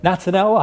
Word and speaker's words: Natanel: Now, sitting next to you, Natanel, I hope I pronounced that right.